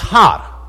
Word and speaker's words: Har: Now, Mount Megiddo is also known as Mount Megiddon, and the Hebrew word for mountain is Har, Har, H-A-R. But very often that H is silent, and so when you can just drop that H, you're left with Ar, Armageddon.